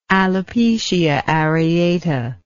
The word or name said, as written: alopecia areata